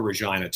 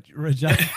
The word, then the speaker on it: Regina: I don't know how that worked, but you had an agri Regina. I mean, I know the joke writes itself. – The city that rhymes with fun, they say, yeah.